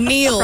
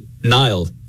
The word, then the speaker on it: Niall: When I was growing up, I would say Nile, like N-I-L-E effectively, like the river.